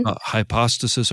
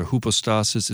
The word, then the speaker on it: hypostasis: Hypostasis or hypostasis. It's got a Y in there, but Greeks pronounce it differently.